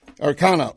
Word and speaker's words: Ro Khanna: Now, the remarks being made in the beginning there were from Democrat, I stress, California Congressman Ro Khanna. Arcana, pardon me, it's for correct pronunciation.